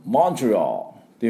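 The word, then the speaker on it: Montreal: ですからモントリオールというふうに発音していただきたいと思います。 M の音とトゥトゥトゥツバを飛ばす、でオールと U をつけないと最後にね、オールで、 AL ですからオールっていうふうに発音してください。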